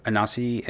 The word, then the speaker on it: Anansi: It's the story of Anansi and his son Isnana. Did I say that right? Make sure. Why don't you pronounce those for me? No, you guys got it.